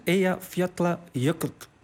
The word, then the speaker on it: Eyjafjallajökull: Dat is Eyjafjallajökull. – Eyjafjallajökull. – De dubbel L wordt uitgesproken en dat is te moeilijk in deze naam. Ja. Als een DL.